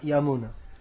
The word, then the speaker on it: Yamuna: It's Yamuna, not Yamuna. The name of the river, the wife of Krishna, is Yamuna. But in this song, by Sanskrit grammatical rules, it becomes Yamuna Tira Vanachari.